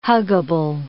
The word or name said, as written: huggable